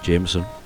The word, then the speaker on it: JMSN: Going for someone called JMSN, but apparently it's pronounced Jameson.